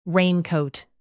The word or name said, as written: raincoat